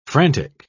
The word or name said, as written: frantic